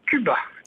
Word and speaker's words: Cubat: Cuba. Comme le pays, mais on rajoute un T à la fin. – D'accord. On prononce Cuba ou Cubat ? – Cuba, on prononce.